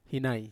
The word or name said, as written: hinai